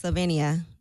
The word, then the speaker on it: Slovenia: Donkey of the Day for Monday, September 14th, goes to a woman who is a citizen of Slovenia. Did I pronounce that right, Yee? Slovenia? – Yeah, I think it's Slovenia. Mm-hmm.